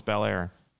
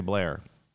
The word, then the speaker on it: Bel Air: The second one was Bel Air. – Weird. – Or as the locals say, Blair.